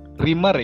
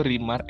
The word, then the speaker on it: Reemar: Rimar ya gue Rimar, E-nya dua. – Oh Rimar, Kudin tadi bilangnya Remar.